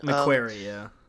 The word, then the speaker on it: McQuarrie: McQuarrie, yeah.